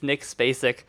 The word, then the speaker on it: Nick Spacek: His name is Nick Spacek.